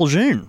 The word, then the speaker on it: Lejeune: He's camped in, and I'm sure I'm going to butcher this, but Camp Lejeune? – Camp Lejeune. – Did I do right? Camp Lejeune.